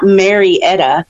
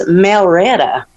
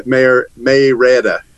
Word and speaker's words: Marietta: She says, "It's not Marietta. It's Malretta." – You know, I actually live in Smyrna, and the town next to me is Marietta. – Yeah. – And that's how we say Mayretta.